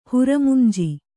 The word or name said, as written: ಹುರಮಂಜಿ